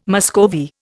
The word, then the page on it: Muscovy: Indian